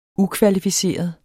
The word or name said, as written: ukvalificeret